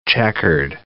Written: checkered

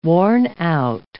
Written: worn-out

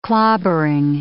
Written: clobbering

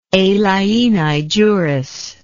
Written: alieni juris

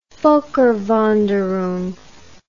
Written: volkerwanderung

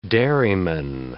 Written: dairyman

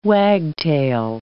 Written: wagtail

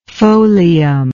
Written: folium

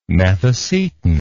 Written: methacetin

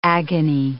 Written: agony

